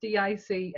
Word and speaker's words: DIC: And I laughed when I found out who did it, because I think it's pronounced DIC Entertainers, not Dick Entertainers.